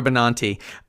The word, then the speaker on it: Benanti: Andre DeShields, Eva Noblezada, George Salazar, Laura Benanti, who they pronounce Laura Benanti,